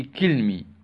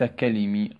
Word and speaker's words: كلمي: في كلمي، وليس كلمي، خطأ، كلمي، في كلمي بالكسر، كلمي.